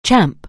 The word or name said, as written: champ